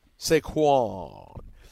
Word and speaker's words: Saquon: Oh, look, you put an accent on the second syllable. – Yeah. Saquon.